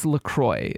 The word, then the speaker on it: LaCroix: Let us know how to correctly pronounce C-R-O-I-X. – So, yeah, and I've gone back and forth with this, and I think it's LaCroix is okay. How they actually do pronounce it.